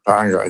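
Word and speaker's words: Paanga (paʻanga): And when you're in Tonga, because you do have a local currency, is it pronounced the Paanga? – Paanga, exactly. Perfect.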